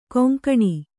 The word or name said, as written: ಘಿಂಘಿಣಿ